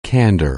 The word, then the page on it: candor: anglais